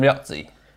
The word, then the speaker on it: Riozzi: Today we're joined by Commercial Director Adam Riozzi.